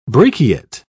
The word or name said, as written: brachiate